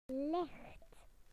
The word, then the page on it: light: British English